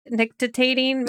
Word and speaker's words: nictitating: It's called a nictitating membrane.